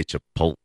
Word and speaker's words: Chipotle: I like how you say Chipotle. – That's how you say it.